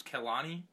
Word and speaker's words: Kehlani: Basically, Kyrie Irving and I believe her name's Kehlani.